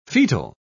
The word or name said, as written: fetal